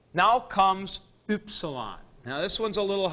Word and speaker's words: upsilon: We get it in words like hym, H-Y-M-N. And that upsilon is pronounced with that umlaut, U, U. It's upsilon, U. Now you can do this best by making your mouth as if you're going to say ooh, and then with your mouth in that shape, you say e.